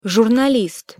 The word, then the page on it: zurnalist: Russian